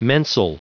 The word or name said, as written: mensal